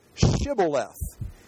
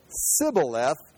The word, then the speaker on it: Shibboleth: They said, all right, say Shibboleth. If he said Sibboleth, because he could not pronounce the word correctly, they seized him and killed him at the fords of the Jordan.